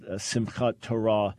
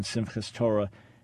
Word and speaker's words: Simchat Torah: And this is also called Simchat Torah, or in colloquial pronunciation, Simchas Torah.